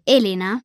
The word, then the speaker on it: Elena: Henriette, Charlotte und Elena, die aus Spanien kommt und deshalb spricht sich ihr Name Elena mit der Betonung auf dem E, sind alle 12 Jahre alt und gehen in dieselbe Klasse.